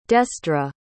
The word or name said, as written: desdra